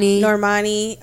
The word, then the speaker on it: Normani: We have like Normani. Is that how you say her name? – Yeah. – Mm-hmm. Normani.